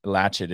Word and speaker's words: Latchit: And it's Latchet, is they spell it L-A-T-C-H-I-T. It's one word, Latchet Rack.